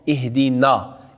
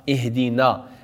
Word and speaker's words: اهدنا: wat ook belangrijk is, waar heel veel mensen de fout in gaan, is die noon van ihdina wordt heel vaak zwaar uitgesproken als ihdina. Ihdina. Het is ihdina.